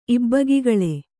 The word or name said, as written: ಇಬ್ಬಗೆಗೊಳ್